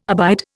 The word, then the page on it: abide: Indian